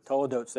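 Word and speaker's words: toledot: Next is this, it's called in Hebrew, the Toledots. Is that how you say it again? – Toledotes, there we go.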